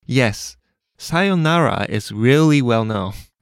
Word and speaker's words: さようなら: You have to make sure to hold the yo sound. さようなら。 – And the whole tone of the word stays flat.さようなら。